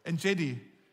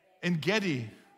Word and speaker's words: En Gedi: Do I say it right? En, en Gedi? En Gedi. En Gedi. Thanks, mate.